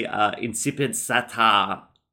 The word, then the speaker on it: Incipit Satan: I mispronounced it. It should be Incipit Satan. Yeah? Is it? – Incipit Satan? – No, they weren't saying Kipit. They were doing some Norwegian variant on it.